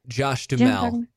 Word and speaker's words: Duhamel: Josh, is it Duhamel, or do you say Duhamel? – Josh Duhamel.